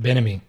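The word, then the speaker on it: Bieniemy: Interviewed Eric Behemi. How do you say it? – Benemy. – Benemy? – Mm-hmm.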